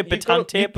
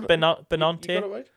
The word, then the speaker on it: Benante: I always want to say Benante, but it's Benante.